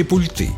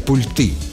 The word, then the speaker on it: пульты: Многие по ошибке переносят ударение на окончание и говорят «пульты». Но литературная норма другая. Правильно, «пульты».